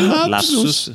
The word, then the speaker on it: lapsus: Questo lupsus freudiano. Non è un lupsus? Non si dice lupsus? Si dice lapsus. – Sì. – Sì. Questo era un lapsus.